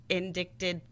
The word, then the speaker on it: indicted: On June 14th, a grand jury indicted... – Indicted. – It's a silent C.